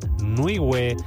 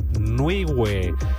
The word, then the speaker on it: Nwigwe: Fue a cargo de Fat Nuiwe, N-W-I-G-W-E, Fat Nuiwe.